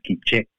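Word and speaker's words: K'iche': Better like quiche, like the French quiche, but it's pronounced quiche.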